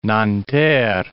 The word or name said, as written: Nanterre